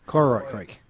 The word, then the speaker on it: Kororoit Creek: I'm going to try and pronounce this, Kororoit Creek? – Kororoit Creek. – Yeah, thank you, this week.